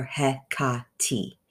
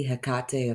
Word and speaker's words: Hecate: Her name, although pronounced in various ways throughout history, is genuinely pronounced either Hecate or Hecate, with an emphasis on the middle.